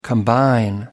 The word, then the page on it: combine: American English